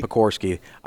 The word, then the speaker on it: Pekorsky: Pekorsky. I always want to leave the R out for some reason.